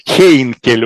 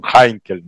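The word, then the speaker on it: Heinkel: Хейнкель у нас произносится, он Хайнкель на самом деле по-немецки.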